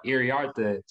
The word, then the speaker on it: Iriarte: So he said, Iriarte.